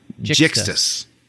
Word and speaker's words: Jixtus: So, yeah, I think it's Jinxus. Let me look at the spelling one last time here. Jixtus. There was no N. Jixtus.